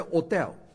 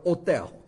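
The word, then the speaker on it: hôtel: 这个 hotel 啊，英文叫旅馆，在法国叫 hotel。法国人不读 h 了，法文没有 h 这个音的。如果读 h，h 读成 r，读成赫，所以就叫 hotel。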